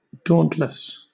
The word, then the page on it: dauntless: British English